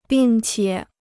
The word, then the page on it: 并且: bìng qiě